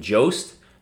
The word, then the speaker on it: Jost: I think the J is silent, my friend. – In Jost? – I think it's Jost. – I think it's Jost. – I think it's Jost. – Well... – It's like yoke.